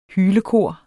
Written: hylekor

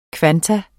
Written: kvantum

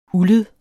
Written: uldet

